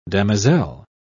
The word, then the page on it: damoiselle: British English